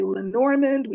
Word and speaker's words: Lenormand: Lenormand would be the French pronunciation, but we say Lenormand, we say Lenormand.